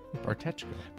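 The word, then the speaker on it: Bartečko: Bartesko or Barteko? – Bartechko.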